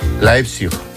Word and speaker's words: Leipzig: Leipzig. ¿Lo he dicho bien? – Espera, qué importante, es saber idiomas. – Tengo aquí a mi amigo que me lo dice. ¿Cómo se dice? – Leipzig. – Leipzig. – Leipzig, ¿ves? – Leipzig, muy bien.